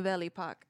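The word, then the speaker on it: Belle Époque: Yeah, the Belle Epoque.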